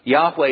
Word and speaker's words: Yahweh: Now, Jehovah then technically would be an incorrect pronunciation of God's divine name. But the fact is that even Yahweh is at best an educated guess, because all we're certain about are the four letters. The actual pronunciation of the divine name is still somewhat debated. Yahweh is probably the best and most educated guess.